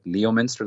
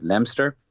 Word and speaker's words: Leominster: to me, it looks like Leominster. That's the way it's written, but I believe the locals pronounce it Leominster.